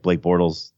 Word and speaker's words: Blake Bortles: I think you said it right, Blake Bortles.